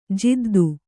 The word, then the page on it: ಜಿದ್ದು: jiddu